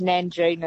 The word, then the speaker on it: Nangeenan: And good on you and your brother, the Nanganeen Town Hall. – So, no, it's not Nanganeen, it's Nanjing, and it's like N-A-N-G-E-E-N-A-N.